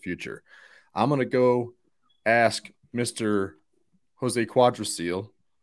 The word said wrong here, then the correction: Alguacil: Am I pronouncing that right? – Alguacil. You combined Cotrero and Alguacil, which honestly is impressive to get those two combined.